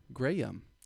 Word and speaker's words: Graeme: So it's Insta, just like it sounds, Gray, G-R-E-Y, and then U-M. – Graham. – Graham. Just how I like for everyone to pronounce my name.